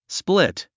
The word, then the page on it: split: splít; スプリット